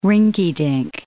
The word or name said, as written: rinky-dink